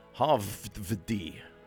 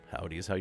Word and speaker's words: Howdy: Although the W is spelt with a double V, so Havvdi, but Howdy is how you pronounce it.